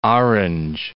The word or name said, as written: Port Orange